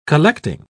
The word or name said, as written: collecting